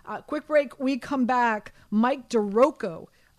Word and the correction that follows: DiRocco: uh, Rocco, sorry, Mike DiRocco. I keep on pronouncing it wrong.